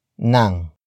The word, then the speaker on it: ng: After that, it's nang. Nang. Nang. Nang is spelled as ng.